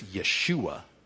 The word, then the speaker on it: Yeshua: Now, I think that's a mispronunciation, because do you pronounce it Joshua or Yeshua? It's Yeshua. But how is it spelled?